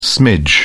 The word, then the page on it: smidge: British English